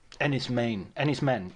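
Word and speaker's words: Enys Men: Ennis Men. Yes, it's pronounced Men, isn't it? The word is for an island. It's Cornish for island, apparently.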